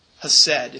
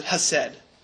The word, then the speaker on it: chesed: Hesed. If you're going to spell it, usually it's spelled C-H-E-S-E-D, but you can just pronounce it hesed.